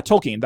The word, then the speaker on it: Tolkien: Yeah, Tolkien. That's how you say his name.